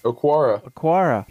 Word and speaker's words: Okwara: Did I pronounce that right? – Okwara.